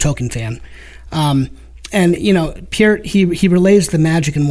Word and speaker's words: Peart: I'll say it correctly. – There you go. – The lyrics were by Neil Peart.